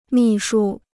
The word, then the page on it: 秘书: mì shū